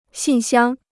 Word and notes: 信箱: xìn xiāng

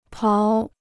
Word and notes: 刨: bào

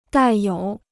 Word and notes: 带有: dài yǒu

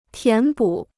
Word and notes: 填补: tián bǔ

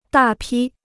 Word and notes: 大批: dà pī

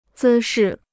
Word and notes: 姿势: zī shì

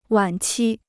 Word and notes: 晚期: wǎn qī

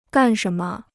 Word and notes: 干什么: gàn shén me

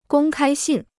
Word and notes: 公开信: gōng kāi xìn

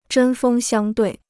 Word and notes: 针锋相对: zhēn fēng xiāng duì

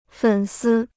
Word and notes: 粉丝: fěn sī

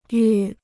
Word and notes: 愈: yù

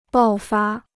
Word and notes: 爆发: bào fā

